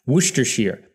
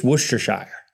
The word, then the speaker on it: Worcestershire: Worcestershire. But I think it's Worcestershire. Worcestershire. It's got shire on the end. It's not sheer.